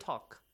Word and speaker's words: talk: Can you spell the word talk? – T-A-L-K. – Oh, L is silent there.